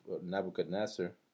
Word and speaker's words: Nebuchadnezzar: As a matter of fact, the name of Nebuchadnezzar, did I say it right? – Yeah, nice.